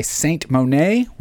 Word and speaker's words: Seint: followed by Saint Monet. We're saying it's Saint. It's spelled S-E-I-N-T, but why not? Why not just pronounce it Saint?